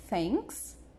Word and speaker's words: thanks: Se você falou thanks, você acertou.